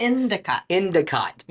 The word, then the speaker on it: Endicott: Endicott.